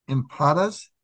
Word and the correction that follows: empanadas: Em- empanadas. Okay. Yeah.